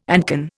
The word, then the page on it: ancon: Indian